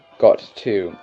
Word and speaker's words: gatto: The word "got to" got to is how you pronounce "gatto." It's not actually "gatto," but you pr- you would pronounce it similar to that, so you would get the idea of how to say cat in an Italian way.